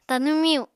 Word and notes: Tanw miyu: Apatani

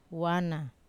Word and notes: wana: Wamesa

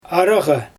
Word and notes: araGa: Xyzyl

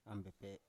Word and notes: ambepe: Biak

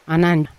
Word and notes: anan: Yawa

